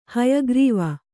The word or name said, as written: ಹಯಗ್ರೀವ